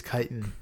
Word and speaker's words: chitin: Remember the chitins. – That's a good way to remember that it is chitin. That's how you pronounce it.